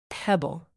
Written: pebble

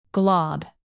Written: glob

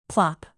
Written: plop

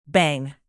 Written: bang

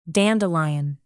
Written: dandelion

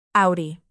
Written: audi

